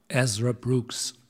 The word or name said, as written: Ezra Brooks